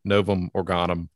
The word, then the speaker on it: Novum Organum: And I'm curious, how widely read was the Novum Organum? Was it, and pronunciation, excuse me. – Precisely right.